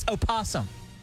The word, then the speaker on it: opossum: Actually, the correct pronunciation is opossum.